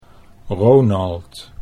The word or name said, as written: Ronald